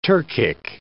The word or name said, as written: Turkic